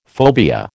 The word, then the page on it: phobia: American English